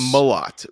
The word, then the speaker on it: Mellott: It's Mellott with a not E. You don't emphasize the E.